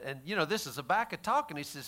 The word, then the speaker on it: Habakkuk: Let's go over to this little book called Habakkuk, just real quick. Go to Habakkuk 1:13, baby. Habakkuk. Is that how you pronounce Habakkuk? Habakkuk. Sounds like tobacco.